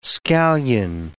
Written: scallion

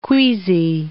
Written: queazy

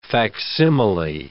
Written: facsimile

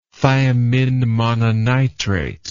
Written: thiamine mononitrate

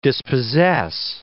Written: dispossess

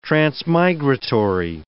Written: transmigratory

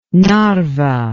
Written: narva

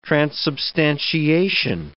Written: transubstantiation

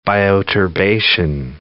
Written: bioturbation

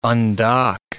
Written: undock

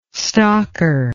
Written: stocker

